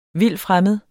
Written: vildfremmed

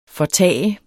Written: fortage

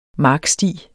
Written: marksti

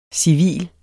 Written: civil

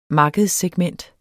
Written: markedssegment

